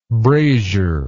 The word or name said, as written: braziery